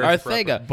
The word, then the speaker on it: Arcega: I love how you said Arcega. – That's the proper Arcega. – Barcelona pronunciation.